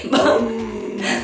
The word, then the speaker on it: bomb: Bomb. Bomb. La primera no, la primera se pronuncia, la segunda no. Bam, bam, bam.